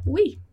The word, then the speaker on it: oui: Em seguida, a gente tem a palavra oui. Oui é sim. E a gente fala oui. Oui. Oui. Né? Então, ali sem segredo, o e o u, som de u. Oui.